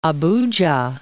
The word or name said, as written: Abuja